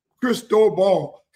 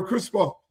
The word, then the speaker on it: Cristobal: See, you don't even know how to pronounce his name. Cristobal. His name is Mario Cristobal.